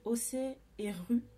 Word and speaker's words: OCE et RU: Elles s'appellent OC et RU. Ça s'écrit OCE et RU, U Et euh, c'est deux filles en fait.